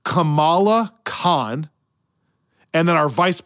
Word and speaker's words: Kamala: It is Kamala.